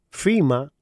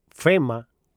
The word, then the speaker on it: FEMA: FIMA o FEMA, como lo pronunciamos en español,